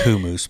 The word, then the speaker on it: hummus: It's really pronounced hummus, but I know most people don't pronounce it that way, so I won't.